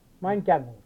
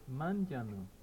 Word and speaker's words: manjanu: So, a word for fishing net, something like mandanu? – Mandanu. Mandanu. Mandanu. – How do you say? – Manda, manja. Manjanu. – Manjanu. – Manjanu. – Manjanu. – Yeah. – Ah, okay, I got that wrong.